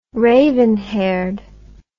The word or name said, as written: raven haired